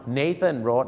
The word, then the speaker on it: Nathan Road: ชาติเดียวที่ออกเสียงว่านาธานครับชาวโลกก็ออกเสียงว่า Nathan Road นั่นเองนะครับซึ่งชื่อฝรั่งนะครับเดี๋ยวถ้าผมมีโอกาสครั้งหน้าเราจะดูนะครับว่าชื่อฝรั่งนะครับหลายๆชื่อที่เราออกเสียงผิดมีอะไรกันบ้างนั้นฝากไว้หนึ่งชื่อครับ Nathan นะครับไม่ใช่นาธาน